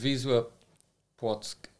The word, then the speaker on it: Wisła Płock: How do you say it? Wisla Płock? – Yeah, pretty good. Wisła Płock.